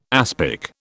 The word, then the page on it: aspic: British English